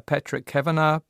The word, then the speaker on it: Patrick Kavanagh: Now, it's been inspired by a Patrick Kavanagh poem, if I have that pronunciation correct, called The Great Hunger. – That's correct, yeah.